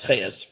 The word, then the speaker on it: Chajes: And Rav Huttner's daughter, Buria Huttner, wrote her dissertation on Maritz Chayus. If you ever see an academic, though, you will not know how to spell it. His last name is spelled C-H-A-J-E-S, right? Chat, whatever. Maritz Chayus, okay?